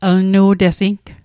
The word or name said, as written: lnu'tesing